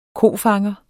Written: kofanger

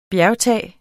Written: bjergtage

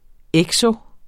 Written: ekso-

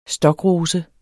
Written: stokrose

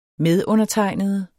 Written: medundertegnede

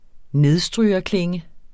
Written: nedstrygerklinge

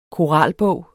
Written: koralbog

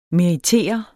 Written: meritere

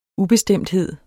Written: UBESTEMTHED

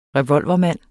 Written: REVOLVERMAND

